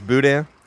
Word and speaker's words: boudin: Okay, so it's boudin.